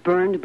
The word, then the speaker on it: Byrne: Have you ever attempted to buy a car and found yourself burned by high-pressure salesmen? I recommend that you see the Burn Men. That's B-Y-R-N-E.